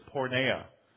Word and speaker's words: porneia: It comes from a Greek word, and the Greek word there is porneia. And I pronounce that word for you only so you can hear it. It sounds an awful lot like our word pornography.